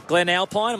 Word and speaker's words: Glen Alpine: Rob from Glen Alpine, am I saying that right? – Yep.